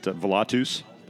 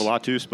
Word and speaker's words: Volatus: You know, I pronounce it Volatus. – Volatus. – Volatus, but it's 2,000-year-old dead language. Volatus means flight or flying in Latin, so most folks can, if they can't pronounce it, they just say the tus.